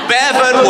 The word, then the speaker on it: Bevan: So what's the bit of his name that rhymes with seven? – Nye Bevans trimming. – No, it's not that. It's kind of a poor Bevan. – Yes, it is in fact.